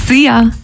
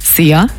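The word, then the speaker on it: szia: See ya. Hallo und Tschüss, könnt ihr euch ganz easy merken. Wird einfach ausgesprochen wie unser Energy-Star. See ya.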